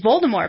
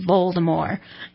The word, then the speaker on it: Voldemort: And then you click on it again, and it says, Voldemort. – You might be the only person in the world who found that. Because there was an uprising when she's like, you don't pronounce the T, and everyone's like, what are you talking about?